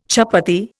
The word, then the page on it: chapatti: Indian